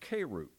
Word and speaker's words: kerux: You have probably heard me use this word, but the word is pronounced kerux. So a preacher was a kerux.